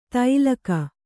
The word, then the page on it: ತೈಲಕ: tailaka